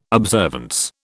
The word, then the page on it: observance: British English